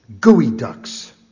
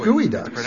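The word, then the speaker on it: geoduck: G-E-O? – Yeah. – G-E-O, and it's not geoducks? – No, it is gooey. It's pronounced gooey ducks.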